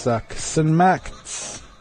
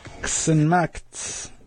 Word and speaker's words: ksenmakt: I work, or I am working, is ksenmakt. Ksenmakt. Ksenmakt is how you say, I am working.